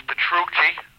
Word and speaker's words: Petrucci: My name is Petrucci. – How do you spell that, please? – P-E-T. – P-E-T? – R-U-C-C-I.